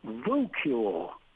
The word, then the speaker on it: voocule: This brand new word is voocule. Here's how it's pronounced. Vu rhymes with boo. Cule rhymes with mule.